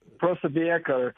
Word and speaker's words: Posobiec: Is that how you pronounce it, Jack Posobiec? – background, I'm trying to remember. Posobiec. – Good to know. – You got to give a little Eastern European in there, right? – Good to know.